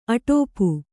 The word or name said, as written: ಅಟಾಪ